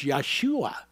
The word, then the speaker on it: Yeshua: Because his name was closer to being pronounced Yeshua.